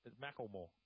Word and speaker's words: Macklemore: Macklemore. Is it sure? – You can hear the L in there. Can you hear it? Macklemore.